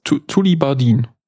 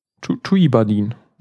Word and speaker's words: Tullibardine: Wie spricht man das jetzt aus? Tullibardine. Tullibardine. Nee, das wäre Spanisch, wenn man es in J aus den zwei L's macht, gell?